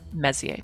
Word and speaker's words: Messier: So M objects, or Messier objects, are from a particular catalog that was made by a man named Charles Messier. Now, he was French, and so the R at the end of his name isn't pronounced.